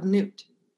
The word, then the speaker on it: Nut: that is the outside of Nut. And her name is spelled N-U-T, but it's pronounced Nut.